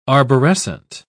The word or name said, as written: arborescent